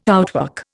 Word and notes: outwork: Indian

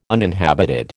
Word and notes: uninhabited: American English

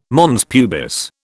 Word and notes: mons pubis: British English

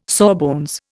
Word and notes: sawbones: Indian

